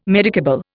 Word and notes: medicable: Indian

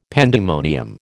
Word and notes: pandemonium: American English

